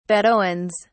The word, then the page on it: bedouins: British English